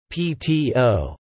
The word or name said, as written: PTO